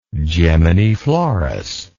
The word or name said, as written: geminiflorous